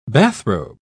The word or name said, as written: bathrobe